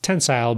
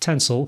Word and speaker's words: tensile: Tensile is pronounced tensile by me, but I've also heard it pronounced tensile, kind of like how you might pronounce the word futile as futile, depending on who you are and where you're from.